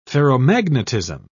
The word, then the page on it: ferromagnetism: British English